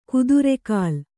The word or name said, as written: ಕುದುರೆಗಾಲ್